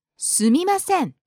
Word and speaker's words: すみません: You noticed that the tone went up on the second syllable. Mi. すみません. And it came back down on the last ん.